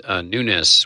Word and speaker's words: Nunes: Well, I think, and David correctly pronounced Nunes, which many of us might have said Nunes, and you may have heard that, but apparently, according to the good congressman himself, that's the way he pronounced it.